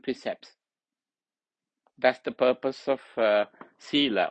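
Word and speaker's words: sīla: Sila, sila. Sila is long A. Use this diacritical mark hyphen to note longer pronunciation.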